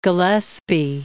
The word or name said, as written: Gillespie